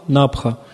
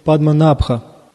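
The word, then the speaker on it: Падма Набха (पद्मनाभ): Падма Набха. Ударение в слове Падма Набха. Набха. Вот это А. В слове Набха. Это туда, это долгое А. Ставится туда ударение.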